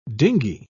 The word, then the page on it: dingey: British English